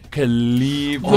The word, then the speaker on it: Calibri: How do you say it? Is it Calibri? – Calibri.